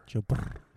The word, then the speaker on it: Burrow: Say his name right. – Joe Burr. – Say it right. – Joe Burr. – There you go, buddy.